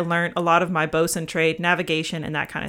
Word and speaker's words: boatswain: A bosun, spelled B-O-A-T-S-W-A-I-N, but it's pronounced bosun.